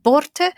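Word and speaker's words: Börte: First of all, do we say her name Borte? That's how I've been saying it. – Yeah, absolutely, yeah.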